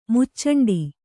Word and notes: ಮುಚ್ಚಂಡಿ: muccaṇḍi

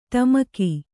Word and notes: ಡುಮುಕು: ḍumuku

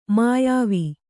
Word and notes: ಮುಯ್ವು: muyvu

